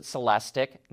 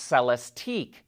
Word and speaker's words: Celestiq: And yes, they pronounce it Celestiq, not Celestique.